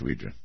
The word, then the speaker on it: Ouija: Yes, I'm quite aware the correct pronunciation is Ouija, Ouija board.